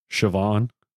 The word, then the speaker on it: Siobhan: Siobhan. It took me forever to figure out how to pronounce that goddamn name. That is a beautiful name, but it is not spelled at all like Siobhan would be.